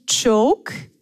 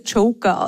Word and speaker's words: Choke: Also es heißt eigentlich Choke, C-H-O-K-E, oft fälschlicherweise Joker ausgesprochen.